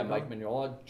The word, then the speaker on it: Mignola: like, Is that how you say his name? – Yeah, Mike Mignola.